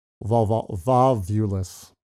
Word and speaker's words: volvulus: I think the species epithet too, like there are multiple ways to pronounce it, but we're going to go with volvulus, volvulus, blah, blah, something like that. It's V-O-L-V-U-L-U-S.